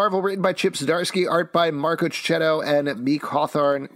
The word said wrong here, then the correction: Mike Hawthorne: Mike Hawthorne, excuse me.